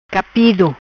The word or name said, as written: Capido